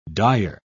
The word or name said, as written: Dyer